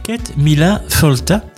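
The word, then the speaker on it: Céad míle fáilte: On prononce Ket Mila Folta, ça s'écrit Seat Mil Felt et on prononce Kate Mila 100 000 fois la bienvenue.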